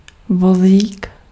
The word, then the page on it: cart: American English